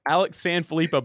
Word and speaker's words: Alex Sanfilippo: Alex Sanfilippo, but you said it with so much confidence, I'll take it.